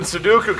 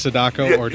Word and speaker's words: Sadako: So then Sudoku comes out of the well, and everything's. – Would you do purposely pronounce her name Sudoku that instead of Sadako? – Exactly. Yeah, that was on purpose.